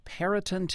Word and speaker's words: Perryton: He's keeping track of the fires from Perryton, Texas. I hope I'm pronouncing that correctly, Jeff. – You got it right, Alex.